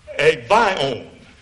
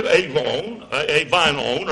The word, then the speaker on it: Avignon: It was moved to France, a city, if I can pronounce it correctly, a Vion.